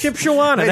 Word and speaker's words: Shipshawana: So I got a hotel room in Shipshawana, Indiana. – Did you say it like that on purpose? – Shipshawana. That's the name of it.